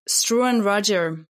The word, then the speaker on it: Struan Rodger: Struan Rodger.